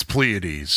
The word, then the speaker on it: Pleiades: Yeah, it's Pleiades. Pleiades. I took an astronomy class in college. It's Pleiades. – There's a King's X song called Pleiades, and if King's X pronounces it Pleiades, it's Pleiades.